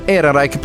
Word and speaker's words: Ehrenreich: Lui si chiama Alden Ehrenreich, mi sembra che lo pronuncino alla tedesca anche gli americani, quindi possiamo metterci d'accordo che si pronuncia così. Alden Ehrenreich